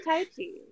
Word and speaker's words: Tai Chi: It was Tai Chi. Am I saying that right? – It's like Nike.